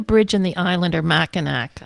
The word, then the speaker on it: Mackinac: So, but I guess they're both pronounced Mackinac. Because Mac is in awe, Lane says.